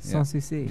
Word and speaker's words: Sans Souci: How do we say it? Sans souci? – Exactly. – Sans souci. – Yeah, there you go. Very French of you.